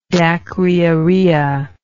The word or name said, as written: dacryorrhea